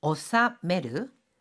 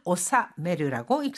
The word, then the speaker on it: 納める: 오사메르. – 取引先に商品を無事に納めました. – 取引先 거래처. 取引先に商品を無事に納めました 하면 거래처의 상품을 무사히 무탈하게 납품했습니다. 자, 이렇게 회비 등을 물다 또는 납품 납입하다 할 때는 오사메르라고 읽습니다.